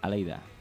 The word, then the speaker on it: Aleyda: Aleida, lo he dicho bien, ¿verdad? – Sí. – Esta vez sí.